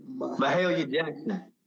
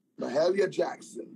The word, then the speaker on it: Mahalia Jackson: Mahalia Jackson. – Mahalia Jackson.